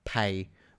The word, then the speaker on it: Pae: Pay, which is spelt P-A-E.